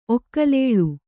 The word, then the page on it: ಒಕ್ಕಲೇಳು: Kannada